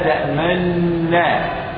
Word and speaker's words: تأمنا: فالنون الأولى مضمومة ونحن لا ننطقها، ولكن ننطق النون الثانية، قرأها النبي صلى الله عليه وسلم كما أقولها أنا الآن: ما لك لا تأمنا. كم نون قرأتها؟ واحدة مفتوح، لكن وأنا أنطق بها أشرت بالشفتين، هذه الإشارة كأنني أشير بالضم لأعرفك أن هناك نون كانت في الكلمة الأولى وحركتها كانت الضم، فأشرت بالضم للشفتين فسموه إشماما. سموه إيه؟